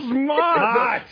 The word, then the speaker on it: Mott: It rhymes with Smot. – It's going to be Mott. – It's Mott. Mott the Hoople.